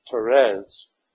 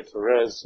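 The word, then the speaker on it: Thérèse: Now, St. Teresa of Lisieux, and I'm going to say Teresa, although it is pronounced Therese, the French way, Therese of Lisieux.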